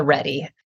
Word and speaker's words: RHETI: And then starting in the early 2000s, the Enneagram Institute developed a statistically validated survey called the READY. And that's the one that you can go on their website. This is the one I use in my practice that we use on our team with our clients. And it's spelled R-H-E-T-I.